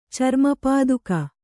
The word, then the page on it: ಚರ್ಮಪಾದುಕೆ: Kannada